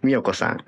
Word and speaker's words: 345: 凛としてしぐれのベースの方、みよこさん、はい。 345 って書いて、みよこって読む。